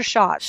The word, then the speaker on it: Schatz: it Schatz or Schatz? – Schatz.